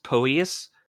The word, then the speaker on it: Poeas: He was killed by, I believe it's pronounced Poeas. It's spelled P-O-E-A-S, with the help of Medea's magic.